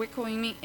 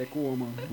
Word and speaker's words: Ecoime: Porque o Ecoime, ele apoia... Ele Desculpa, foi feito como pro... é o nome? – Ecoime. – E eu falei errado, falei Ecooma.